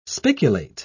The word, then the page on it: spiculate: British English